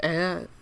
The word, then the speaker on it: Aer: Uh, probably not a game you've heard of um I think it's called Air. It's spelled A E R.